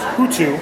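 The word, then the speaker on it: hủ tiếu: I got the what's apparently pronounced hutu, mm-hmm.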